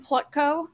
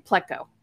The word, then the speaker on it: Plutko: Adam Plutko, no, wait, that's not how you say his name. Plutko, that's it.